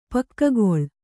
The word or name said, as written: ಪೊಗೆಗೊಳ್